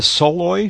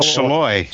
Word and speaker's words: Soloy: How do you say the Soloy? S O L Soloy. – Soloy.